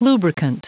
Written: lubricant

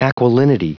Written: aquilinity

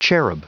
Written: cherub

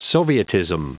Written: sovietism

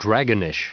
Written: dragonish

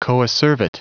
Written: coacervate

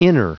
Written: inner

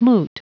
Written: moot

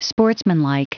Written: sportsmanlike

